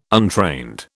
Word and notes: untrained: British English

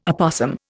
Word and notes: opossum: Indian

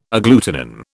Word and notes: agglutinin: British English

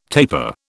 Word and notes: tapir: British English

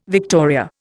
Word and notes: victoria: Indian

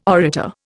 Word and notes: orator: Indian

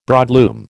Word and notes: broadloom: American English

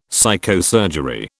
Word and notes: psychosurgery: British English

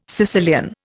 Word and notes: Sicilian: Indian

